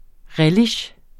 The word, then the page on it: relish: ˈʁεliɕ; ˈɹεliɕ